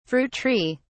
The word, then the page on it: fruit tree: British English